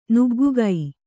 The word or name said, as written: ನುಗ್ಗುಗೈ